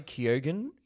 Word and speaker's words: Keoghan: Barry Keoghan is apparently how we're pronouncing that.